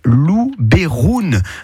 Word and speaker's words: Luberon: En provençal, en fait, le E se prononce E. Donc, si vous dites Luberon, ben, c'est presque la bonne prononciation d'origine. Voilà.